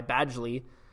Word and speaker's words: Badgley: It's Badgley.